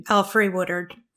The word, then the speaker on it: Alfre Woodard: Alfre Woodard. – Alfre Woodard? – Yeah.